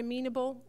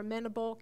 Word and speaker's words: amenable: That word amenable or amenable can be pronounced either way.